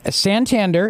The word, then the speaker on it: Santander: By the way, Santander, it's a bank, and I've opened you an account there.